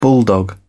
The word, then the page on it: bulldog: British English